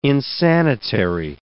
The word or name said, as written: insanitary